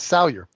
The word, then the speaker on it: Salyer: Uh, yes, Salyer.